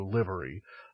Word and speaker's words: livery: Which he's clarifying for us, is pronounced as in live it up, so livery, not as in live performance, library.